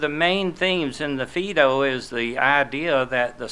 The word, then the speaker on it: Phaedo: Plato's work is called Phaedo. That's spelled P-H-A-E-D-O. Phaedo, P-H-A-E-D-O, is a dialogue which depicts the death of Socrates.